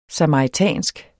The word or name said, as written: samaritansk